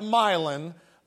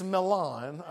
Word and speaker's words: Milan: I know in West Tennessee we call that Milan, but it's Milan, okay? That's the right way to say it.